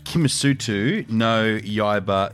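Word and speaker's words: Kimetsu no Yaiba: Slayer, Kimo no Yumba, Infinity Castle. – I'll give you 2 out of 5 for that. That's wasn't great. Demon Slayer, Kimisutu no Yaiba, Infinity Castle. That's how you say it.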